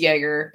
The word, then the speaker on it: Yeager: And the Yeager family, and I think it's Yeager because I went to high school with someone with the same last name and they pronounced it Yeager. So it's with a J.